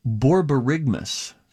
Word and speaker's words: borborygmus: No, the technical word is pronounced borborygmus. B-O-R-B-O-R-Y-G-M-U-S. Borborygmus. Oh, do you guys want to go to Subway?